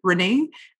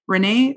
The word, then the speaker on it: Renee: It's my first name, R-E-N-E-E, Reneé, at R like Rubicon, M like marketing dot agency. So that's my email. You can find me on Facebook, Reneé, R-E-N-E-E. And my last name is spelled B like boy, O-U-D like Donald, A-K-I-A-N like Nancy, Reneé Boudakian.